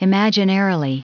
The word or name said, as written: imaginarily